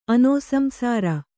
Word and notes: ಅನೋಸಂಸಾರ: anōsamsāra

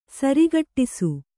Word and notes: ಸರಕಟಿಸು: kannada

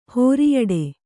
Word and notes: ಹೊರಿಯೊಟ್ಟೆ: horiyoṭṭe

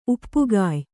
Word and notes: ಉಪ್ಪುಗಾಯಿ: kannada

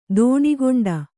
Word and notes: ದೋಣಿಗೊಂಡ: Kannada

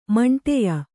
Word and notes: ಮಂಠೆಯ: kannada

